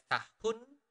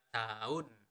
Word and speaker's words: tahun: We usually do not really pronounce the letter H. So we do not really pronounce it as tahun, but tahun.